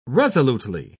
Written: resolutely

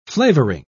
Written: flavoring

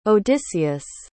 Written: odysseus

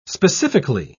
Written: specifically

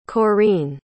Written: Corene

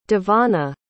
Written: davonna

